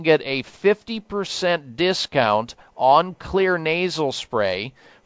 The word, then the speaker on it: Xlear: It's called Clear Nasal Spray or Kids Clear Nasal Spray. They have two, one for adults. The Clear Nasal Spray, it's spelled X-L-E-A-R. The X stands for xylitol, and then you have Kids Clear Spray.